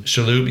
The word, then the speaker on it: Shalhoub: Victor Kershaw is Tony, how do you say it? Shalhoub? – Tony Shalhoub. – Shalhoub, yeah. I like his character.